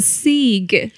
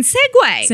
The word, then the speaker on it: segue: Because I was like, you know, a sieg. And then one day I realized, that's fucking segue. – Segue. – That's the word is segue.